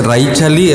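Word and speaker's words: Raíchali: Una de las personas que lo puso en marcha, se llama Raichali, pero que seguro que lo pronunció malísimo, es súper preciosa la explicación en vuestro idioma de qué significa Raichali. – Sí, es Raichali, es naciendo en la primera I, Raichali significa palabra en Rarámuri.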